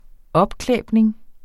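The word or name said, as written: opklæbning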